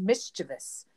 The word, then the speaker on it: mischievous: There's not an I in the end of that. – There's not. It's just mischievous.